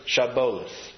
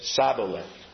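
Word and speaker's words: Shibboleth: And when he said no, they said to him, "Then say Shiboleth." You know, with the H in there, S-H-I-B. And he said Siboleth, without the H, for he could not pronounce it right.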